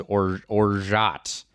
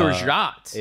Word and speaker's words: orgeat: I will correct you only because I wanted to compliment that item, so I looked up the pronunciation, and it is orgeat. – It's orgeat.